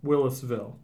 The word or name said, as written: Willisville